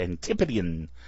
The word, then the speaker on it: Antipodean: Antipodean Podcast. That's how they pronounce it. Got to throw that tip in there. It's Antipodean.